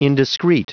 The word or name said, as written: indiscreet